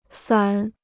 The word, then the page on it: 伞: sǎn